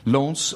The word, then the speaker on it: Lens: Lens, how you pronounce it? Lens? Lens? – Lens.